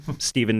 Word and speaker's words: Stephen: And then if you want to find me, you can either Google my name, Stephen Nicholas Knight, STEPHEN, Knight spelled with a K, or that's my Instagram handle too.